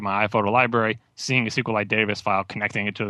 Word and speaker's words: SQLite: says how to pronounce it. So anyway, I'm saying SQLite. You're gonna have to deal with it.